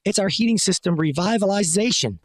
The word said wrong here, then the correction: revitalization: It's more like revitalization.